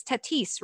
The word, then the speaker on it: Tatis: Another baseball story, Trevor Bauer versus Fernando Tater Jr., as Ashley has always called him, because I now can't pronounce the guy's name because it's Tatis, right? – Yeah. – Sure. Yeah, but in my head I hear Tatis because that's what Ashley said.